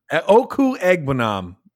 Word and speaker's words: Okwuegbunam: Albert Okwuegbunam? – Yeah. – Exactly. Okwuegbunam.